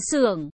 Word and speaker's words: Xưởng: Như ông gì, viết liền, không đọc Vĩnh Nhật, mà đọc là Xưởng.